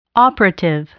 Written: operative

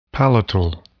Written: palatal

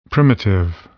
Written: primitive

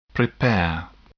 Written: prepare

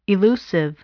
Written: elusive

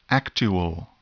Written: actual

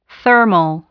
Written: thermal

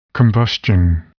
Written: combustion

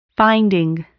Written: finding

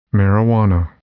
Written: marijuana

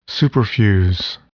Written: superfuse

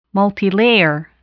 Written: multilayer

